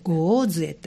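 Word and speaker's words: 牛玉杖: 牛の玉の杖と書いてゴーズエというんですね、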